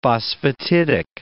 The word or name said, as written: phosphatidic